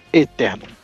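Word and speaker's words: Aeterno: É Eterno, com A-E, tipo E, então é pronúncia Eterno.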